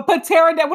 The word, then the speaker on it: pterodactyl: A pterodactyl? What is a pterodactyl? – Pterodactyl. – You do realize the P in pterodactyl is silent, right? – No.